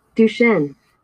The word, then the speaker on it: Duchenne: Dutching? Cannot be saying that right either. I'll make sure you get some of these right. I'm getting a lot of these names wrong. Duchenne. Oh, Duchenne.